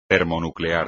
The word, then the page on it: termonuclear: espagnol